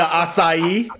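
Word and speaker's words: açaí: Because I went in there, I was like, "Can I get the strawberry acai refresher?" He was like, "You mean acai?" acai. You mean the acai?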